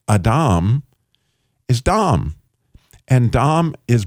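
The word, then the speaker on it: Adam (אדם): The word Adam, which, by the way, is the word for people in the Bible, in the Old Testament, Adam is the way you would pronounce people. Well, that ah sound is the letter Aleph, which means the father.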